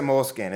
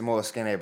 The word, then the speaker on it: Moleskine: Use the, you know, I love a good, you know, Moleskine notebook that I, and you can't pronounce it Moleskine. It's a Moleskine, by the way.